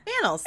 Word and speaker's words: annals: it was forever in the annals. How does one say that? – Annals. A-N-N-A-L-S. Annals. Yeah. – Okay, cool. Wasn't really sure how we pronounce that first A.